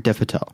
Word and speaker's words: Dev Patel: Dev Patel.